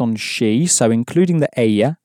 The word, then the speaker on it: ella: Paella is spelled P-A and then E-L-L-A. And of course, because you know how to pronounce paella now, you can pronounce the word for she with that double L sounding like a Y inside. So, keeping in mind it's spelt E-L-L-A, how would you say she has in Spanish? – Ella ha.